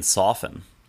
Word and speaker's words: soften: I'm still thrown off by the fact that you pronounced a T in soften.